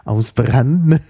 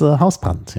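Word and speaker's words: Hausbrandt: Also der wird natürlich auf Italienisch Ausbrand, okay. – Ausbrand ausgesprochen. Oder Ausbrante. – Ja. – Aber es ist Hausbrand, ja.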